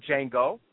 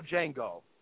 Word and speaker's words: Django: The original Django made an appearance in this one. So I thought that was pretty cool. And the way they did it, where he said, the whole D is silent thing.